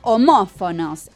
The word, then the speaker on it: homófono: Se llaman homófonos estas palabras. – Homófonos. ¿Cómo se escribe homófono, de paso? – Con H. – Ahí va. – Y aparte es una palabra, ¿sabes qué? Esdrújula. – Claro, lleva acento.